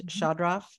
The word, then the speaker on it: Chodroff: And I'll spell it Chodroff as in Frank. That's Chodroff. That's how it's pronounced.